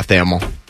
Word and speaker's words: Thamel: If they can, he said, Pete Thamel, or, yeah, Thamel. Yeah, it rhymes with camel. I always have to remember that. Camel, Pete Thamel.